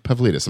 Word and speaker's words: Pavlidis: And my guest today is Nick Pavlidis.